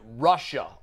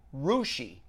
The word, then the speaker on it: Russia: It's actually pronounced Rushi. – Ah, it's spelled Russia, R-U-S-S-I-A, but it's pronounced Rushi.